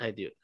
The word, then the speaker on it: Hideo: H-I-D-E-O. – Oh, Hideo. Okay. – Hideo Kojima. – Oh, said Koji at the front, but wouldn't say Kogi. But anyways, let's keep going. Like current Hideo.